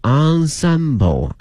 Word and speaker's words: ensemble: 那么后面看一下一个乐团啊，叫 ensemble，拼写的是 ensemble，但是呢它的发音呢是一个 ensemble